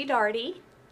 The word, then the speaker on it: Daugherty: Okay, I'm Christy Daugherty, rhymes with party.